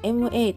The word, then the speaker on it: 吗: そして最後の一文字、ままは ma というふうに発音しますが、形成になり、軽く添える音になります。